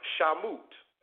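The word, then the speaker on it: Shemot: Exodus is pronounced Shamut.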